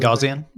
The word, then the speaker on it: Gaussian: And it's using a Gaussian? How do you say that word? – Gaussian? – Gaussian blur.